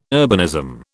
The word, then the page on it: urbanism: British English